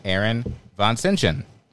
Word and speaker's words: Aaron Vansintjan: and Aaron Vansintjan.